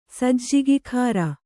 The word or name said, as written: ಸಜ್ಜಿಗಿಖಾರ